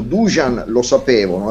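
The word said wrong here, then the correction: Dušan Vlahović: ha detto Vlaovic mi hanno mandato un messaggio due persone serbe che mi fa molto piacere, ascoltano la mia trasmissione e mi hanno detto l'assoluta pronuncia è Dusan Vlahovic e quindi io lo pronuncio in questo modo.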